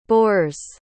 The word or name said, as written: bors